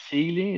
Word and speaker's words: Seeley: Great film with Drew Seeley.